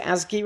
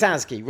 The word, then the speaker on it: ASGI: We pronounce it ASGI, right? ASGI. – I mean, yeah. Pronouncing ASGI is always difficult. But it's WSGI, so it's ASGI, right? – It's ASGI, right.